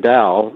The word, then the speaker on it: Dao: That was meeting meeting Dow D A O. This is the way you say his name, Dow, and Dow meant something.